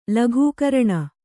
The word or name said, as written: ಲಘೂಕರಣ